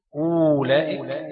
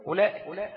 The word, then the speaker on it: أولئك: طيب انت لما تنطقها بتنطقها ايه؟ اولئك، تنطقها ايه؟ اولئك، همزه مضمومه بس، يعني كان الواو ايه؟ مش موجوده، صح كده؟ يبقى ده ثابت ايه؟ رسما، لان مرسومه ومحذوف ايه؟ محذوف في اللفظ، ما بنطقهاش.